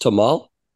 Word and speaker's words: tamal: Have I been saying tamale wrong? Is it pronounced tamal? – It's tamal. It doesn't have an E at the end.